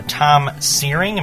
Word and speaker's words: Searing: And I know most of the songs were written by Tom Searing. Am I saying that right? – Yep, that's right.